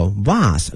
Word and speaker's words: vase: vase V-A-S-E 哈它有两个发音一个是 vase 一个是 vase 哈都可以哈没有问题哈但是呢它的拼写都是一样的 V-A-S-E 但是发音啊，不同的国家可能呢有所不同